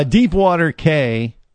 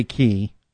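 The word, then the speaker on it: Deepwater Cay: Deepwater K, or you could say Key, because that's how they say it in the Bahamas. – So you could say it the way they do. – Yeah, well, that's nice. – Everyone call it, you know, that I've never quite understood that, why they do that, because it's Deepwater Key is how they say it, but it's actually spelled C-A-Y, Deepwater K.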